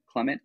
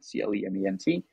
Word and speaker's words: Clement: So they can find me on Twitter at Aldrin Clement, which is just my first name and last name, as it's spelled, like Buzz Aldrin, Aldrin, Clement, like Clementine, Clement.